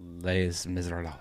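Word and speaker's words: Les Misérables: How do you say that? – Les Miserables.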